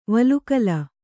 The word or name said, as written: ವಲ್ಕಲ